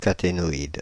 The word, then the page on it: caténoïde: France (Île-de-France); /ka.te.nɔ.id/; Paris; [ka.te.nɔ.id]